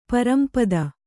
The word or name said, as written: ಪರಂಪದ